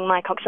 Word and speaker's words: Mycocs: Good morning, Mycox Electrical.